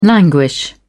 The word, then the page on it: languish: British English